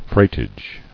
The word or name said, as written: Freightage